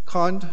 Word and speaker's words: Comte: Comte schreibt man C-O-M-T-E. C-O-M-T-E.